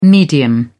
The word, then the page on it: medium: British English